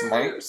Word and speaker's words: Szmyt: How do you pronounce that name? S Z M Y T. Who cares? Smite. Smite. Smite.